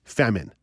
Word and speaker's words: Famin: But if you are interested in learning more about Ugo, it's spelled U-G-O, Ugo. And the designer is Famine, so F-A-M-I-N. But Ugo, U-G-O, is where you can learn more about this tactile stove top.